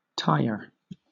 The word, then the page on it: tier: British English